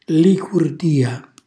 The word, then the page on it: licurdia: Calabrese